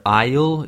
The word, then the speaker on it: aisle: It's a strange word because it's spelled A-I-S-L-E. And it's pronounced kind of like the contraction for I will, like I'll.